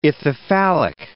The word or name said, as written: ithyphallic